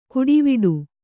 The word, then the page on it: ಕುಡಿವಿಡು: kuḍiviḍu